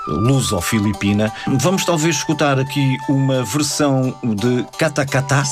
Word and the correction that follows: Katakataka: Sim, Katakataka. – Katakataka. – Isso. – Falta uma sílaba.